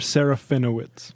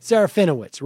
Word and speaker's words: Serafinowicz: We hope Seferovitz. Can you pronounce his name for me, please? – Peter Serafinowicz. – Serafinowicz, right.